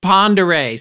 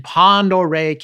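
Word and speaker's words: Pend Oreille: Up past Spokane, where you climb into Stevens and Pendore County. Did I say that right? – Pondere.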